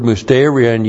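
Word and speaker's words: Musterion (μυστήριον): The Greek word is musterion. M-U-S-T long E-R-I-O-N. Musterion.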